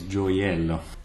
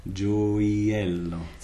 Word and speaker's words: gioiello: So we have, first of all, we have the G followed by the I, which makes it a soft J. – Right. – And a voice J. And then we have the I, which is really here just a J glide, going into E. Eh. Gioiello.